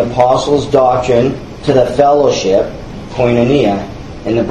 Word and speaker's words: koinonia: as koinonia. That's the pronunciation.